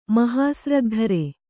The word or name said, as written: ಮಹಾಸ್ರಗ್ಧರ